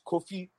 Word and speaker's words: Kofi: Like Kofi, all right, it's Kofi. Say it like that. Kofi. – Kofi. – Kofi. – Kofi. – Right. Yeah, that's how you say it.